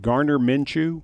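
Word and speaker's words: Gardner Minshew: Garner Minshew? – Yep.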